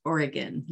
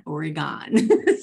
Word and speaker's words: Oregon: And it's pronounced Oregon, not Oregon.